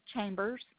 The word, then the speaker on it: Chambers: L-A-U-R-A. – And your last name? – Chambers. – Is it C-H-A-M-B-E-R-S? – Correct.